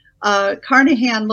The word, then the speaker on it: Carnachan: Carnahan looks like Carnachan.